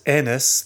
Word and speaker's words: Ennis: And now all I have to remember is that Irish Garth has Ennis the same as Enniskillen.